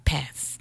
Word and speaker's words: path: A light on my path. 네, 번데기 발음 path.